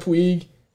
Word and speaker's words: Puig: They have Yasiel Puig. – Yeah. – Did I say that correctly that time? – Yeah, you're straight away from Puig. – Puig.